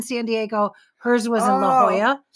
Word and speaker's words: La Jolla: She must not have been in La Jolla anymore. – La Jolla. – La Jolla. Why do I keep saying it wrong? – I don't know.